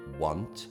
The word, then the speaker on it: want: Allora, want è, pronuncia in italiano sarebbe U-O-N-T, want, mentre negativo a will, c'è un U in più, è U-O-U-W-O-U, U-O-U-N-T, won't. Quindi se ti dico non vorrò una birra, I won't want.